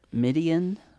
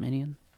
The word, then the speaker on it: Midian: He's in a desert, Midian. Am I saying that right? – Mm-hmm. – Midian.